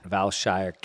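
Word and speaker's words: Vailshire: You can find him on Twitter at Valshire Cap. That's pronounced like Vail and Shire Cap.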